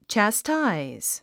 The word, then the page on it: chastise: tʃæstáiz; チャスタァイズ